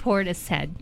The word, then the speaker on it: Portishead: Portis head.